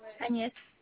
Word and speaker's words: Agnès: Agnès.